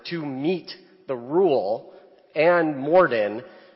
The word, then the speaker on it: Morden: Is that how we pronounce it, Morden? – Yeah.